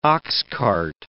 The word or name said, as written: oxcart